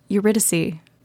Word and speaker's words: Eurydice: Quick note. I fucked up. It's pronounced Eurydice. My bad.